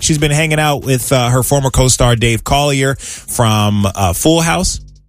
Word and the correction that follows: Coulier: You don't like how I pronounced his name? – I didn't. – Collier? – Isn't that Coulier? – Uncle Joey?